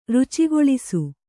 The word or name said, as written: ರುಚಿಗೊಳಿಸು